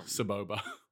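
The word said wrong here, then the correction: Sebulba: Oh, that would be Sabulba.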